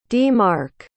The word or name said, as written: d-mark